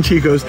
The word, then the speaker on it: Csikos: It is C-S-I-K-O-S. Chicos.